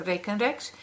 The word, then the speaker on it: rekenrek: Well, first of all, thank you so much for giving me the opportunity to talk about uh, Rekenreks. I'm pronouncing it here the original Dutch way.